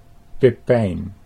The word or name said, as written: Pippijn